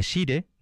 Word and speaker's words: Sire: Este fragmento que vamos a escuchar, la primera parte del dúo, termina con el sire, señor, que dice Tálvela dirigiéndose al rey, con respeto. De sire, dos sílabas, si y re, el re es quizás la nota grave, más grave, escrita entre las óperas de repertorio.